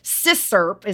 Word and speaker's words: CISCRP: So it's CISCRP is how they say it, CISCRP.